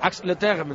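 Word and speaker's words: Ax-les-Thermes: Territorio descendente justo hasta cuando queden 10 kilómetros y medio, porque en Axel Stermes... Bueno, es una pronunciación mala, pero ya me comprenderá. Axel Terme, ¿no, Quique? – Sí, perfectamente.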